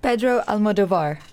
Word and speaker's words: Almodovar: Pedro Almodovar.